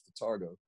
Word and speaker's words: Vitargo: Nowadays, I use Fotargo. I swear by Fotargo. – Fotargo? – Hell yeah. – How do you spell that? – V-I-T-A-R-G-O. – V-I-T-A-R-G-O. Okay. – It's V-I-T-A-R-G-O.